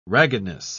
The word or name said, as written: raggedness